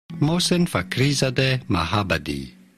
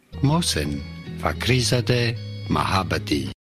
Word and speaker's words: Mohsen Fakhrizadeh Mahabadi: Mohsen Fakhrizadeh Mahabadi. Mohsen Fakhrizadeh Mahabadi.